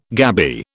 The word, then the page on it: gabby: British English